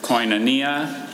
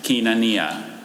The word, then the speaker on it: koinonia: There's koinonia and kinonia.